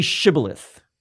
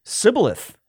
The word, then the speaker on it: Shibboleth: So they said to him, "Say Shibboleth." And they'd say, "Sibboleth." And he could not pronounce it right.